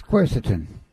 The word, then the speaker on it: quercetin: But one of their protocols, they say for people with low risk, if you can't get the hydrochloroquine, is to use, how do you say, quercetin? – Yes, quercetin.